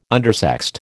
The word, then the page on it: undersexed: American English